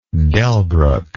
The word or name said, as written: Delbruck